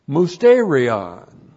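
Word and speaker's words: musterion: Also, the church age is declared to be mysterion. Technical word. We get the word mystery from it. Mysterion. M-U-S-T, long E-R-I-O-N.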